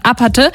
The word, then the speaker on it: APT: Rosé von und Bruno Mars sind ein echtes Dream-Team und feiern den Release von APT ausgesprochen APATE. Denn Rosé kommt aus Südkorea.